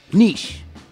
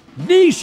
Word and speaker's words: niche: Niche. – Niche is correct. – Yeah. – Not niche.